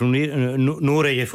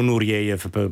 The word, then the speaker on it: Nureyev: E questo era appunto il Valentino con Rudolf Nureyev o Nureyev, pronunciatelo come volete.